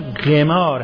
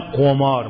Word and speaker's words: قمار: خب استظهار دارید که قمار از نظر به اصطلاح تبار و ساختمان مصدر باب مفاعل است، ولذا تلفظ درستش هم قمار هست نه قمار.